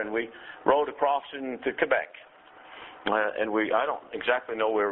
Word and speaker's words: Quebec: I at one time was taking a trip up into Canada, in Quebec, Canada, and that's the right way to pronounce that. It's not Quebec, it's Quebec.